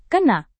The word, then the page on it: kena: indonesian